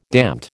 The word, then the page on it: damned: American English